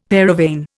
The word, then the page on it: paravane: Indian